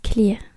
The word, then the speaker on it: Clear: Et je vais vous dire, euh, ça vient de quel, euh, quel auteur Alors, ça vient de James Clear. Donc, James, comme ça se prononce, quoi. Et Clear, comme clair Claire, quoi, mais en anglais.